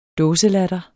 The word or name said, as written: dåselatter